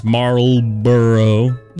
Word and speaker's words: Marlboro: Now, this one I do mispronounce because I am from the South and I just say it real quick. Marlboro. – Marlboro. – But it's Marlboro.